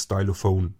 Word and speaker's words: Stylophone: In Englisch wird es übrigens Stylophone äh, ausgesprochen.